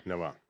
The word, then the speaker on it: Novant: And that company was trying to sell to Novant, right? I said that right. – Yes, Novant.